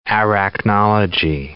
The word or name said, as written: arachnology